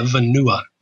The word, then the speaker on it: Vanua: How do you pronounce it? – Yeah, Vanua.